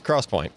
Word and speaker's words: XPoint: Oh, I thought we were talking about Xpoint. – Yeah, I passed out. I was doing some shots. – Bourbon. – Buddy. – You don't do shots. – Yeah, it is pronounced Crosspoint, by the way.